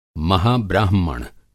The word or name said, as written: महाब्राहमण